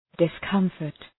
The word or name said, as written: discomfort